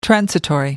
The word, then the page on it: transitory: American English